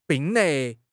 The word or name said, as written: 并列